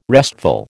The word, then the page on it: restful: American English